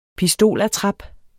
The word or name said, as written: pistolattrap